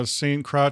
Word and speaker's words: Croix: I was on the St. Croix River. That's how you pronounce it in French.